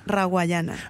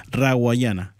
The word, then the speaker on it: Rawayana: Acá te dejo otra canción de Rayaguana, La Playa. Así se dice, perdóname. ¿Cómo se dice bien? Decime. – Me parece muy tierno como lo pronuncias. – ¿Cómo es? – Rawayana. – Rawayana.